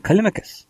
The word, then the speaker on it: Callimachus: So it's been requested by Callimachus? Callimachus? Yeah, yes. I pronounce his name Callimachus.